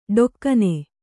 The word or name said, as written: ಢಾಕಿನಿ